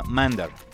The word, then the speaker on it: MNDR: La gente de MNDR, por cierto, si quieren conseguirlos en alguna discotienda, es más fácil que los pronuncien como Mandar. MNDR es como ustedes van a conseguirlos a través de la web, pero se pronuncia Mandar, el nombre, si les interesa.